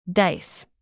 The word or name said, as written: dice